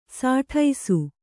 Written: ಸುಡಿಸು

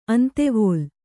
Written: ಅಂತೆವೋಲ್

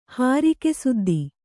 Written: ಹಾರಿಕೆಸುದ್ದಿ

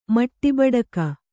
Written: ಮಟ್ಟಿಬಡಕ

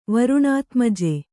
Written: ವರುಣಾತ್ಮಜೆ

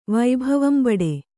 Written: ವೈಭವಂಬಡೆ

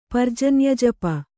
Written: ಪರ್ಜನ್ಯಜಪ

